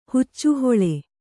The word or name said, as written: ಹುಚ್ಚುಹೊಳೆ